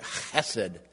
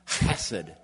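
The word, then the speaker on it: chesed (חֶסֶד): You have to roll that first consonant, chesed, chesed.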